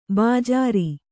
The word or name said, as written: ಬಜ್ಜರ